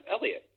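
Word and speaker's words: Heliot: That's not how you pronounce his first name. – No. I was watching the Giants game, and they had his family there. It was his first game in San Francisco. Not his first major league game, but first game in San Francisco. It's pronounced Elliot Ramos. – I wouldn't have guessed that. – A silent H. – The H is silent.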